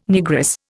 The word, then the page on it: Negress: Indian